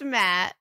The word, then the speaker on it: matte: mate black. They have four different colors. And this one. Did I just say mate black wrong? How do you say it? – It's matte.